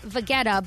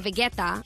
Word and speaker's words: Vegeta: Well, in English, I think it would be Vegeta, but in Serbian, we say Vegeta.